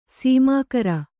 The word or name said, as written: ಸಮಗಾರ